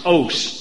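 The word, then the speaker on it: os (אות): The Hebrew word translated mark is os, just two letters, O-S, long O.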